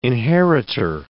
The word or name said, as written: inheritor